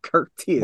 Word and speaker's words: Khurtiss: It's Curtis Perry, but Curtis is spelled K-H-U-R-T-I-S-S. Curtis.